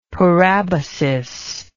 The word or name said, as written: parabasis